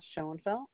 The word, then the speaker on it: Schoenfeldt: Glenda Schoenfeld. – Spell that name, please. – S-C-H-O-E-N-F-E-L-D-T.